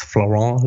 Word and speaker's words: Florent: You have to say it with a loose jaw. It's Florent Lebrun.